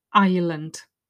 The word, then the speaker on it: island: The first mention is, he says, the next walk is to Rushton Island. So first thing you notice is it's a silent letter. I-S-L-A-N-D. They love silent letters.